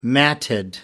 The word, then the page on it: matted: American English